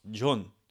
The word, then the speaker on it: John: If you were to put it phonetically, you might think, you know, it's a ja sound, ja, John. However, that is not the case. John is in fact pronounced John. John. It's the yo sound because it's written O-H-N.